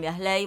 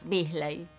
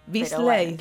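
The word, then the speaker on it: Beazley: El Chino en Biasley, bueno, nosotros pronunciamos ley en el barrio. ¿Y cómo se, se dice Bisley? ¿Cómo es? Bisley. Bisley. claro, porque es en inglés, pero decimos ah, por porque cómo se escribe.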